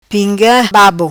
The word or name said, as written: Pinga Babo